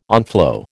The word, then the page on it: onflow: American English